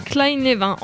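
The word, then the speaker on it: Kleine-Levin: C'est un syndrome qui s'appelle Klein-Levin. Voilà, je ne sais pas si je le prononce bien. En gros... – Klein-Levin.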